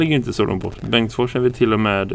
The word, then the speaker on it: Bengtsfors: Men Bengtsfors är det. Bengtsfors. Eller Bengtsfors. Med betoning på fors.